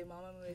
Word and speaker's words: Mamamoo: I always get nervous when I say your name because a part of me feels like I'm saying it wrong, but I'm not, right? – Mamamoo라는 이름을 할 때 약간 긴장을 하신대요. 그 발음이 맞는지 마마무가 맞는지 편하게 해달라고. – Just say comfortably, Mamamoo is fine.